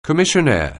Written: commissionaire

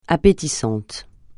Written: appétissant

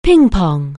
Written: ping-pong

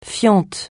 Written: fiente

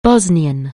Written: Bosnian

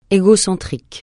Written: égocentrique

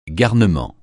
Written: garnement

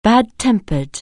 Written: bad-tempered

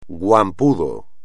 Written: guampudo

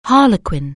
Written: harlequin

